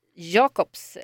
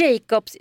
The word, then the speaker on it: Jacobs: Hold Me Closer, Cornelia Jacobs, eller Jacobs. – Jag... Det kan uttalas både och.